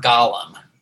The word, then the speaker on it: Golem: I think you can say it either way. I've heard it pronounced both ways. The Golem seems to be the one that's more common, but, you know, tomato, tomato. – You golem. Can say it both ways.